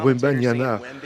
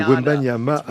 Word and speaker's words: Wembanyama: J'en ai marre d'entendre Wembanyama. C'est Wembanyama avec un M. Même mon fils de 5 ans sait dire Wembanyama.